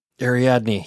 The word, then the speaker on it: Ariadne: So anyway, another one from Adriani, sorry. – Are you sure it's not Ariadne? – Ariadne. Yes, there's the pronunciation guide at the end.